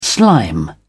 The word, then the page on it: slime: British English